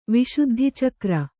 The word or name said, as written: ವಿಶುದ್ಧಿಚಕ್ರ